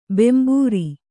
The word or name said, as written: ಬಾಂಬರು